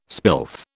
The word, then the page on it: spilth: British English